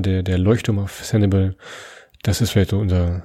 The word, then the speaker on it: Sanibel: Du siehst, am Ende haben wir uns auf deine Aussprache mit Sanibel geeinigt, Christoph.